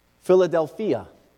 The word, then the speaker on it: Philadelphia: Philadelphia is actually how it's pronounced in the Greek.